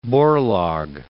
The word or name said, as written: Borlaug